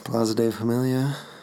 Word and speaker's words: Plaza de Familia: So anyway, that is Plaza de Familia for you people. I probably said that wrong too. – Nope. Sounded great.